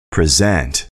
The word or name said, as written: present